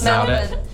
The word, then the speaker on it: Melbourne: Melbourne. – Of course. – Melbourne.